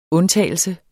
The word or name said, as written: undtagelse